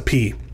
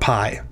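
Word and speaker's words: P (π): you're supposed to pronounce the P. And by the way, it is pronounced P, not pi.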